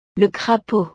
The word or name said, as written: le crapaud